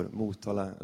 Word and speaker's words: Motala: Och då ser man att en stor skylt där står Motala, Östergötlands huvudstad.